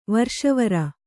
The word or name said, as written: ವೀರೇಶ್ವರ